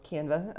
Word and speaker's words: Canva: or Canva, as I've been told I need to pronounce it, and, you know, short for Canvas.